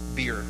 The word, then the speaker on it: bier: People are toting this body on this bier, right? Not the kind you drink. It's the kind you put dead bodies on, right? Sounds the same, but it's pronounced.